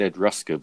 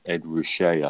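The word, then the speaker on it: Ed Ruscha: Well, I thought his name was pronounced Ed Rusker, but when you read it, it's actually pronounced Ed Ruscher, I think.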